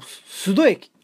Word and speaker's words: 須戸: で、この後須戸駅っていう駅なんですが、これ須戸って読めないんですよ普通。実はですね、高須クリニックの須に、あと三重県の県庁所在地ある津って書いて須戸って読むんですけども、てっきり鈴駅かと思っておりましたが、須戸駅、こちらのね駅へ行くつもりでいたんですが、なぜか道に迷いまして、あれあれあれなんて言ってる間に新幹線の効果が見えてきましてね。